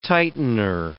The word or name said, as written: tightener